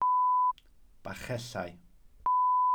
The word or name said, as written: bachellau